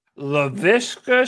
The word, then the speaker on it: Laviska: The how do you say his first name? – LaViska Chenault. You better get used to saying that.